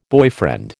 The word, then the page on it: boyfriend: American English